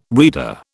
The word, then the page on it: reader: British English